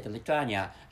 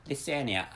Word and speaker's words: Lithuania: And I said Lithuania as one of the Baltic countries, but actually, the correct name is Lithuania. I checked out the pronunciation, and the Lithuania is a correct pronunciation of the country, which is located in the southmost part among Baltic three countries, and other countries are fine.